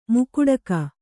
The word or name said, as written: ಮುಕುಡಕ